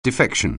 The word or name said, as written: defection